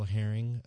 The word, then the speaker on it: Herring: And my email address is L Herring. My last name is spelled H-E-R-R-I-N-G, like the fish.